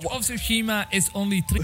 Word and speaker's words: Tsushima: No, it's Ghost of Tsushima because the T isn't pronounced. And I said it to him before, and he goes, "Oh, well, no, there's a T there."